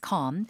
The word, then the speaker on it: calm: Calm. L 발음은 거의 그냥 하지 않는다라고 생각하시고 길게.